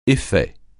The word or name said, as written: effet